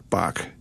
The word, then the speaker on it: Bach: Bach, but the musical tones, B-A-C-H, or to pronounce them in German, B-A-C-H. B-A in German is not B, it's B-flat. So it's B-A-C-H.